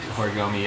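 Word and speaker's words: Horigome: Horigami, is that how you say it? – Horigami, yeah.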